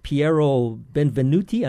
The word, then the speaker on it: Piero Benvenuti: And joining us by phone all the way from Padova, Italy, we have Piero Benvenuti, and I hope I'm pronouncing that correctly. Yes, you do.